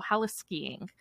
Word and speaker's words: heliskiing: He's also known as a pioneer of, I think it's pronounced heliskiing, because it comes from helicopter, so heliskiing,